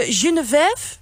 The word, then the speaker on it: Genevieve: Ik loop even door naar Genevieve. – Vief? – Zeg ik het goed? – Ah, helemaal goed.